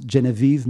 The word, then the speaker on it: Geneviève: Je pense que ça se prononce Genevieve, mais je vais le prononcer. – Oui, tout à fait. – On vous parle en français, là, je vais le dire Geneviève.